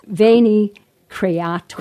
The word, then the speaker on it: Veni Creator: Veni? – Veni Creator.